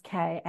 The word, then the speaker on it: Ka: used to call the soul the car body, pronounced Ka, and which is the part of us that incarnates into other physical experiences.